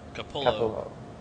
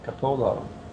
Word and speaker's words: Capullo: By writer Scott Snyder and drawn by Greg Capullo. Capullo. I don't know how to say his name. We'll go with Capullo. – Capullo.